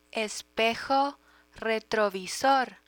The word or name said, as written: espejo retrovisor